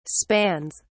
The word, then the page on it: spans: British English